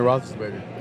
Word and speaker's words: Roethlisberger: Ben Roethlisberger.